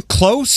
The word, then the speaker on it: Kloss: John Close, spelled like Carly. – Yes. – K-L-O-S-S, who appeared in the Teenage Dream video.